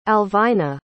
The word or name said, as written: alvina